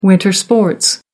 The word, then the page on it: winter sports: American English